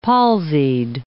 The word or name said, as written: palsied